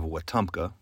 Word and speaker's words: Wetumpka: About six miles northwest of Montgomery, Alabama, near the town of Wetumpka, which happens to be pronounced exactly as it is written, the Tallapoosa and Coosa rivers meet to form a waterway known as the Alabama River.